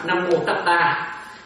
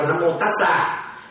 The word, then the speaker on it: Nam Mô Tát Đa: Nên đó sau này á một số chữ tăng Miến Điện đi ra ngoài đọc là Nam Mô Tát Đa chứ không có được là Nam Mô Tát Sa.